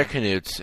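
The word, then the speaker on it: Knuts: I actually think they're pronounced newts. – Really? – Yeah, actually. – Yeah, they're canutes. If you look on the Scholastic pronunciation... – Canutes or newts? – Canutes. – Oh, canutes.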